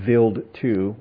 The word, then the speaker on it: Wild 2: a satellite that went to this to Vild 2, which is a comet. It's spelled out Wild 2, but it's German, so it's Vild 2.